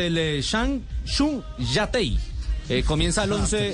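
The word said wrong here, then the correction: Changchun Yatai: Espere, así se pronuncia. – Chanchun Yatei. Chanchun Yatei.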